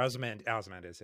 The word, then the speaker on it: Ozymandias: Osmandias, like from um, Watchmen. I cannot pronounce that correctly. – Um, yeah, or Ozymandias, yeah there is a character in the Hulk comics who's like this kid who's smarter than the Hulk.